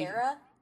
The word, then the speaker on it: Vieira: And then once they went into syndication, they had Meredith Vieira, I believe is how you say that last name. Apparently, Vieira? – Yeah. – Meredith Vieira? – Yes.